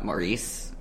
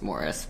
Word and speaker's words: Maurice: Forrester's novel Maurice, or I think it's actually pronounced Morris,